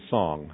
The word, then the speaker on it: Song: Actually, their names are, if you saw them spelled, they're Sing and Song, okay? But Song is actually pronounced Son, but Sing is Sing, okay?